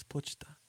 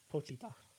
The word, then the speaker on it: Pochita: I think you're saying it wrong. – He is saying it wrong, but we got What is this? – It's Pochita. – Pochita.